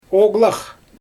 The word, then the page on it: oglax: Xyzyl